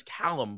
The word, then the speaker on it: Calum: I kept going, before we went live, I said, your second song, I know the guy's name is Calum, but I keep saying Calcum, like Calcum. – As in talcum powder or some, or calcium. I'm like, it just looks like, wait, it's spelled wrong, but it's not.